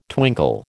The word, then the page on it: twinkle: American English